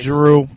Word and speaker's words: Giroux: where he goes off on Claude Giroux, I guess. Is that how you say his name? – Claude Giroux from the Flyers, yeah.